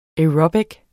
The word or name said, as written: aerobic